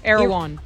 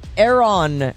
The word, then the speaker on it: Erewhon: Erewhon. – Erewhon.